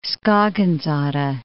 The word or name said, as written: Skagens Odde